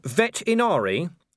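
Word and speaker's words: Vetinari: "Lord Vet Inari," said Vimes helpfully, putting a slight stress on the first syllable and hearing the growl on cue.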